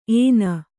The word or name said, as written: ಏನ